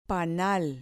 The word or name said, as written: panal